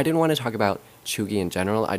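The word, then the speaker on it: cheugy: But it says Chugi, pronounced Chugi.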